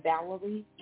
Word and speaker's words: Valerie: You can reach me on Facebook under Valerie Yvette, V-A-L-E-R-I-E. My middle name, Yvette, spelled with a Y, Y-V-E-T-T-E.